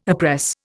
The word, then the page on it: oppress: Indian